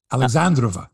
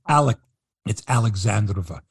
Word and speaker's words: Alexandrova: How would you say that, Mike? – You're on your own here. – Alexandrova. That's it. It's Alexandrova. Okay. It's Ekaterina Alexandrova. Thank you.